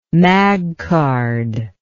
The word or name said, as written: magcard